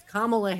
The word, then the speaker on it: Kamala: It's Kamala Harris. I hope I'm saying that correctly, like a comma, the punctuation mark, Kamala Harris.